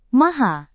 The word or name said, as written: maha